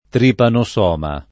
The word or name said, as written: tripanosoma